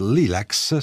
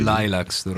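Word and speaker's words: Lilacs: Ok, in questo disco che ho tra le mani, che porta il titolo di Midnight Lilax, si pronuncia così. – Lilax, ecco, lo sapevo.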